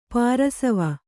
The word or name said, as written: ಪಾರ್ಶ್ವ